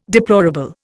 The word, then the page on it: deplorable: Indian